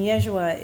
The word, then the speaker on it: Yeshua: And Yeshua is the original Aramaic pronunciation of Jesus' name.